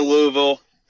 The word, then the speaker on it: Louisville: So I'm out here in Louisville.